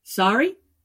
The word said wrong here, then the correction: Sarai: Is it Sari? – Sarai. No. It's actually Sarai.